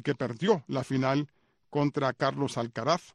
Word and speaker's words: Carlos Alcaraz: Carlos Alcaraz, lo que pasa es que tuve que ver a Efraín para ver si lo estaba pronunciando correctamente. Carlos Alcaraz, ¿verdad? ¿Está bien? Ok.